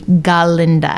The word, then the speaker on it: Glinda: She had the big dream when she was 13 at a New York show watching Wicked to one day be Glinda.